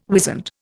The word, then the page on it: wizened: Indian